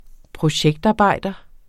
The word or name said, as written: projektarbejder